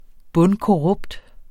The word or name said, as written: bundkorrupt